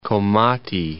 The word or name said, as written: Komati